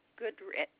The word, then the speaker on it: Goodrich: Laura Goodrich. – Can you spell that last name for me, please, ma'am? – G-O-O-D-R-I-C-H.